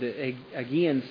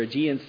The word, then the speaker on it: Aegean: The very word, Aegean Sea or Aegean Sea, depending on how you pronounce it, literally means sea goat.